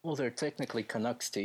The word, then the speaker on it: Canucks: So yeah, this one is known as the Stanley Cup Riots, and it was the aftermath of the match between the Canucks.